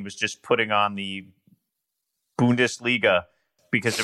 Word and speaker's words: Bundesliga: I learned how to say, but I've since forgotten, Bungledisha. Is that how you say it? – Bungledisha. – Bundesliga. Right? – Bundesliga.